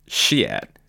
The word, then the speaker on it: Chiat: The night's only damper came from the MC, who repeatedly mispronounced Chiat's name. It's Chiat. Oh, Chiat.